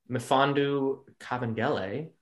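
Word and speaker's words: Mfiondu Kabengele: And then Mifondu Kabengele, who is Dikembe Mutombo's nephew.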